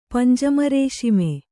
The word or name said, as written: ಪಂಜಮರೇಶಿಮೆ